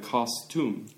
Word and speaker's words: costume: T-O-M-B, 그거는 무덤이라는 네. 의미잖아요. 그거 발음이 하고 비슷해요. costume. 그리고 그 custom하고 달라요. custom은 풍습. custom, 풍습. 그리고 이거는 costume. costume.